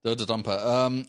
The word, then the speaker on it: Dodonpa: Where is the Dondonpa roller coaster located? – Man, they haven't even got the name right. It's the Dondonpa. The Dodonpa. Dodonpa. – Yeah, that's probably just my pronunciation. It's Dodonpa.